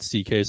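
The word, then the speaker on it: Szekely: And like Louis C.K., like his last name is pronounced C.K., so he literally started putting C.K. – Oh, the letters. – So, yeah, but that's not his last. Yeah, because no one would say it right.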